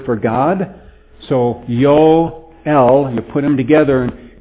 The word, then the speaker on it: Yoel: The name Joel, actually Hebrew technically does not have a J sound. So normally in Hebrew you would pronounce his name Yoel.